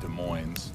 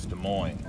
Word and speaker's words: Des Moines: You're in Des Moines, and it's not Des Moines, it's Des Moines. It's a silent S, right?